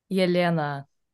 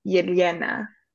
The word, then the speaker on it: Yelena: When you say the L sound, you like knock the back of your front teeth with your tongue. So it's like Yelena.